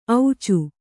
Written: ಔಚು

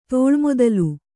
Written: ತೋಳ್ಮೊದಲು

